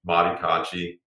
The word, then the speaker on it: Mahdi Cocci: Mahdi Cocci, just spelled like my name, M-A-H-D-I-C-O-C-C-I.